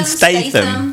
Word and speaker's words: Statham: Jason Statham. There's no R. – He's from the East End of London as anyone else. So it's Jason Statham.